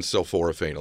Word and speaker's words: sulforaphane: So I'm learning about sulforaphane. Did I say that correctly? – Yeah, sulforaphane.